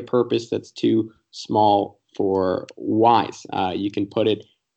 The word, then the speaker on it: Wyze: Um, Wyze, uh, spelled W Y Z E, even though it's pronounced like W I S E is the uh, home camera that you need.